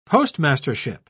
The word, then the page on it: postmastership: British English